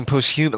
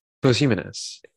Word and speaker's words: posthumous: We're saying it wrong. I was saying posthumous, like hummus. Like we were like, we need the posthumous. – You said hummus. It's posthumous.